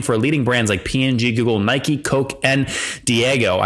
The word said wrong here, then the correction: Diageo: Yeah, did I get that last brand right? – Diageo. – Diageo, very good.